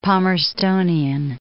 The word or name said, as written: Palmerstonian